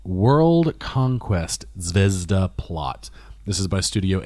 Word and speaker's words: Zvezda: It looks like it should be Zvezda, but the Japanese pronunciation is Zvezda.